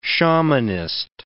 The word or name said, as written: shamanist